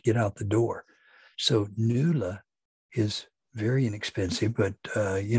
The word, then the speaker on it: Knuula: Now that's spelled K-N-U-U-L-A, NULA, and it is for engagement letters.